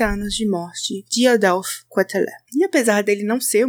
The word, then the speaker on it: Quételet: Nosso influencer hoje é Adolphe Quetelet, e sim, a pronúncia é assim em francês.